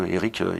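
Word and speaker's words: Erick: Et mon nom aussi, Eric D'Imperio. Eric, il y a une petite particule, ça s'écrit avec un C et un K.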